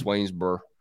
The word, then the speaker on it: Swainsboro: You don't really pronounce that O in Swainsboro. I don't even think you pronounce the final letter. It's just Swainsboro.